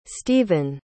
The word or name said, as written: stephen